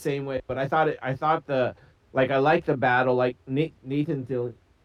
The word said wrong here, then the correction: Fillion: Fillion, right? – Nathan Fillion.